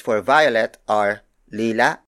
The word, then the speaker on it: lila: The first non-native color is lila